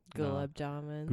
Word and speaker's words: gulab jamun: The donuts and the gumanjalan. Is that how you say it? – Gulab jamun. – Gulab jamun, yeah.